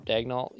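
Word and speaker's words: Daigneault: I can never pronounce his last name. Diakno? – Like Dagnall or something like that. – Dagnall, yeah, Dagnall.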